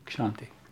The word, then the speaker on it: Kshanti: Anyone knows how to pronounce it properly? Kshanti.